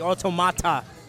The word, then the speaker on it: Automata: I like how you enunciate the T, Automata. – Basically, the pronunciation, though, is correct.